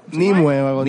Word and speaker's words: Nimue: Nimue, Nimue.